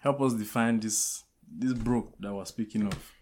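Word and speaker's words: broque: It's broke. I will spell it. It's B-R-O-Q-U-E.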